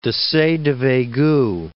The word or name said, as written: Desaix de Veygoux